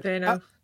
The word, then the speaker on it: Dano: and is it Paul Dano or Paul Dano? Am I saying his name right? – Dano.